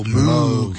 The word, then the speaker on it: Moog: Caller comment pronounced it with a long O. Moog. – Moog.